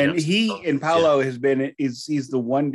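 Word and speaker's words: Paulo: And you've got Andreas Kisser on lead guitar and Paolo Jr. Is that how you say it? – Yeah, Paolo. Paolo. – It's just Paolo.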